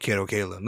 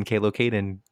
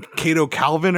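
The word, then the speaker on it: Kato Kaelin: Can you say his name again? Why do you keep mispronouncing? You've mispronounced his name. – What I call him? – Four times that you've said it now. – Yeah, you call him Cato Calvin at one point.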